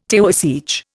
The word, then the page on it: Taoiseach: Indian